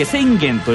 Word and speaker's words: 浅間: 知らなかったですね、横浜駅前なんですね、近くにはですね、あの浅間と書いて千元と読む地名の交差点があるんですけれども、これも鳥用によってはあの本物の軽井沢の浅間山の気分を醸し出してなかなかのリゾート感覚でございます。